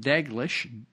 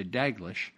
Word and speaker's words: Dalglish: So right here in the Guardian, British Guardian newspaper, a guy named John Dalglish, D-A-L-G-L-I-S-H, Dalglish, if you pronounce the whole thing and don't leave any silent letters anyway. I want to say Daglish, but it's got an L in there, Dalglish.